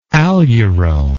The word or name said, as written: aleuronic